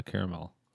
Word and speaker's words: caramel: There is a sweetness, but I'm not getting any caramel. Caramel. – Thank you for saying it correctly.